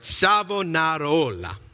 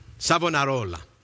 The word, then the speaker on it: Savonarola: But it's Savonarola. No, let me say his name right. Savonarola. Savonarola.